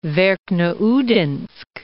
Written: Verkhneudinsk

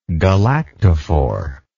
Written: galactophore

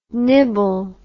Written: nybble

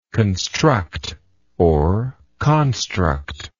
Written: constructible

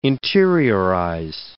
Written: interiorize

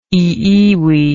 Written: iiwi

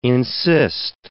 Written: insist

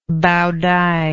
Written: baodai

